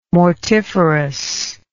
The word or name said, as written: mortiferous